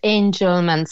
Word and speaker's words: Angelman: Your little boy, is it Angelman syndrome or Angelman syndrome? Morning. – Hi, good morning. It's Angelman syndrome.